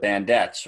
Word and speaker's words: Bandettes: So for people listening, if you've ever been to a Bandits game, they have a dance team and they're pronounced the Bandettes, right? – Yes.